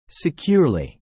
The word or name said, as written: securely